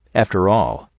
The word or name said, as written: after all